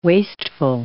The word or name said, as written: wasteful